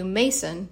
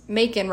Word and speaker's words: Macon: they call it the Bayou Mason, but it's spelled like Macon, Georgia. And so when I got the notes back to do pickups for my audiobook, the producer said, "You mispronounce Macon. It's pronounced Macon rhyming with bacon." And I said, no, like everyone there said Mason, like I'm not going against what people told me.